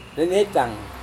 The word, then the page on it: vemetang: Serewen